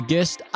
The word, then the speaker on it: Gist: Is it called The Gist? I don't even know anymore. It's just all we go with the out. soft G for the gist.